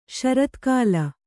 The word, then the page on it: ಶರತ್ಕಾಲ: Kannada